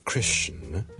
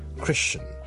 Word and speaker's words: Christian: 有 more than one acceptable 的 pronunciation，例如基督徒我們 Christian， Christian C-H-R-I-S-T-I-A-N Christian，很多時候我就這樣 Christian 就可以了。